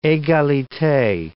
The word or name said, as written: egalite